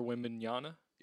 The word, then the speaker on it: Wembanyama: In other news, the Spurs will be getting Victor Wembanyama.